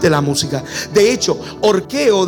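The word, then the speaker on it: orqueo (ὀρχέω): El término es orqueo, se escribe orceo, O-R-C-E o orqueo.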